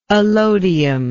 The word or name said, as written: allodium